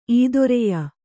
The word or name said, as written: ಈದೊರೆಯ